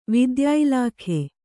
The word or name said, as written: ವಿದ್ಯಾಇಲಾಖೆ